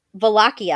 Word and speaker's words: Wallachia: He was the Voivod of Wichala. Wallachia. I'm sorry. I call it Wichala because that's how it's spelled. Like it looks like in English, but it's called Wallachia.